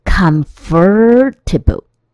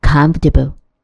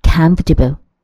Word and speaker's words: comfortable: 第五个单字是舒服的，c o m f o r t a b l e，这个单字也是非常多同学会念错的，很多同学都会念成 comfortable 或是 comfortable 都不对。那个 o r 看起来的音标是很像 her h e r 那个 e r 的音。但是实质上它是不发音的，它会念成 comfortable，comfortable。 Please make yourself comfortable. Please make yourself comfortable.